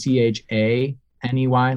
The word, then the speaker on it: Chaney: The last name is Chaney, like Lon Chaney, for anyone. Maybe I'm old. For anyone who's silent film actor. It's not spelled like Dick Chaney. It's like Lon.